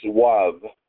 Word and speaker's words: Vav: The Vav, which is pronounced Wav, the third letter of the holy name, corresponds to the realm of Yetzirah in the worlds.